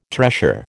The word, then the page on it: tressure: American English